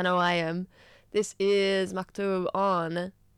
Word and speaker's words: Maktub: Maktub.